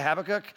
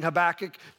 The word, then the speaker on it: Habakkuk: Some of you may say Habakkuk. I'm saying Habakkuk today.